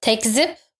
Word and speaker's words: tekzip: And then if that slander is wrong, and then you have to write a correction, teskip is the Turkish word, you put it to the fifth page on the bottom. – Isn't it tekzip? – Tekzip, yes, I said it wrong.